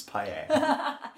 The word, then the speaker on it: paella: Oh, can I start by complimenting you on your pronunciation of paella? – Thank you. – It is very close to a correct Spanish pronunciation. – Oh, thank you. – But most British people would say paella, which is not great.